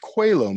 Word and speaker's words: Coelho: And that became it's pronounced Coelho Medallion. And it's spelled Coelho, but it's named for Paulo Coelho, the writer, the author of the Alchemist.